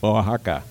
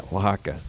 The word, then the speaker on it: Oaxaca: Oaxaca. – Okay. Got it. Yeah, Oaxaca.